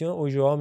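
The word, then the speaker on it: Ulloa: با دوتا ال نوشته میشه داشتش لستر سیتی اوژوا میگفتن گزارشگر لاتین اینم احتمالا روجی هم چیزی تلفظ میشه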